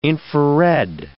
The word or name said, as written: infrared